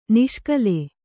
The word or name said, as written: ನಿಷ್ಕಲ